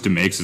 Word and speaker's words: DeMakes: Uh, Chris DeMakes, is that how you say it? – That is exactly how you say it.